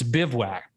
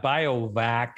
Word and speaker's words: bivouac: It's bivouac. Bivouac. – Yeah, that one doesn't look like it sounds either. – No, bivouac. it's not biovac, it's bivouac. So the emphasis is on the first syllable.